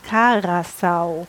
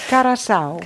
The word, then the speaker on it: Carasau: Das heißt auch Pane Karasa. Carasau. Carasau. Carasau. ja. – Wie Curacao.